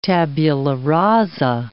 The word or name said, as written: tabula rasa